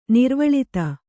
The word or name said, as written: ನಿರ್ವಳಿತ